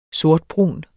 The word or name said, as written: sortbrun